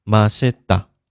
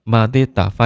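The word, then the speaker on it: 马 西 达 (맛있다): 呃，没有错，但是呢，生活当中更常说的是马西达，马西达，因为比起这个马蒂达发音和马西达，对吧？很明显，这个马西达听起来更加呃发音的流畅一点，对吧？所以我们生活当中呢就读马西达就可以。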